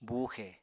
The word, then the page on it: buuxe: apatani